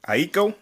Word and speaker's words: Aiko: Summer 2020, Janae Aiko, or is that how you say it? – Aiko. – Yep.